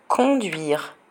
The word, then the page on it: conduire: French